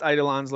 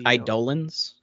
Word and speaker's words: Eidolons: Eidolons? The only reason I know that is because I'm pretty sure that's what they call summons in 9, so I think it's Eidolons.